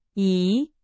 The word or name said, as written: 已